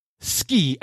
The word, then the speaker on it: Ski: I mean, I saw what she wrote down, S-K-I. And so it is pronounced Ski, S-K-E-E.